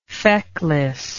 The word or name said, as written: fecklessness